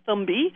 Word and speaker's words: Thumby: So it does feel like a thumb when it's used, and that's how we came up with the name, Thumby. Also, the original model was green and flexible and fun, and so it rhymes with Gumby also.